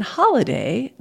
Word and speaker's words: holiday: So that's why we have a different vowel in holiday than we do in holy day.